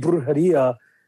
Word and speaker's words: Brujeria: Brujeria.